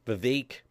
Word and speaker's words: Vivek: But there's even, they're playing out that Vivek, rhymes with Cape, Ramaswamy, might actually, that could become the ticket of him and President Trump.